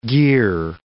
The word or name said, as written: gear